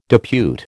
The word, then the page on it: depute: American English